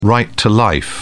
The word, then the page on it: right to life: British English